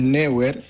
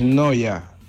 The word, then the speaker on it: Neuer: si no estoy mal y no estoy equivocado como usted, el portero aquel mencionado anteriormente, ¿sí?, no es Neuer, es Noya.